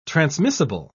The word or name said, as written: transmissible